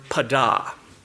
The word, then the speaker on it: padah: Now moving to the Old Testament, in the Old Testament we see the word redeem, and in many places it's the word, the Hebrew word padah. You can spell it P-A-D-A-H.